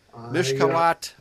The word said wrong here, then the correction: Mishlakat: Malakei Royim. Presumably. Mishlakat, sorry.